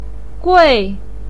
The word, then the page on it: gui4: pinyin